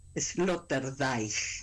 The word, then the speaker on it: Sloterdijk: que se llama Peter Sloterdijk.